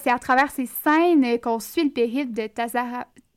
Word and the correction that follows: Tazama: de Tazama. oui, de Tazama, exactement.